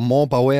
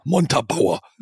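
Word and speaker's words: Montabaur: D Montabauer und ich habe das, ich hab das jahrelang falsch ausgesprochen. – Ich habe immer Montbauer äh, oder so. Und dann habe ich äh, das einfach deutsch ausgesprochen. Montabauer.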